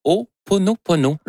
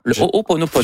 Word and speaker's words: Ho'oponopono: Non, c'est une philosophie hawaïenne de, je vais le prononcer comme il faut, de Ho'oponopono. Ho'oponopono.